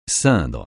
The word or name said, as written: ceindre